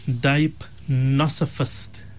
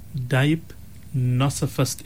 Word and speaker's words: deipnosophist: So the word that we were looking for, Molana, is a dipnosophist. Dipnosophist. It's spelled DEIPNOSOPHIST. Dipnosophist.